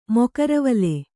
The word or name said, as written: ಮೊಕರವಲೆ